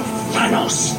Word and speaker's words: Thanos: This is how he says we should pronounce the big purple guy all because of Thanos.